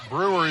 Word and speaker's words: Brewer: Brewer is actually the pronunciation. Some people who go there only use one syllable and call it Brewer.